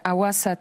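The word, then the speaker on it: Hawassa: Salam, dokładnie, tak, zgadza się, Awasa, tak to się wymawia.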